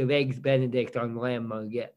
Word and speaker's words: merguez: Yeah, merguez, yep.